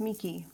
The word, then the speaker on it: Meike: Oh, I like the French pronunciation. Thank you. It's Meike.